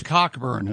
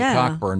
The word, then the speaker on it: Cockburn: Cockburn, as I used to say. – It's spelled Cockburn, but it's pronounced Cockburn.